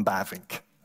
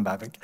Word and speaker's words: Bavinck: and ik moet zijn namen op een Hollands uitspreken, Abraham Kuyper and Herman Bavinck. In English, we call them Abraham Kuyper and Herman Bavinck.